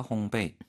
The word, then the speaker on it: 烘焙: 이 글자 자체가 배가 연상이 되는 언뜻 보면 그러나 정확한 발음은 烘焙 이렇게. 배. 그래서 빵을 굽다 베이킹하다 烘焙.